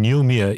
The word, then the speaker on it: Ngulmiya: And Yulmia is spelled N-G-U-L-M-I-Y-A, Yulmia.